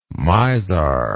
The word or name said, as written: mizar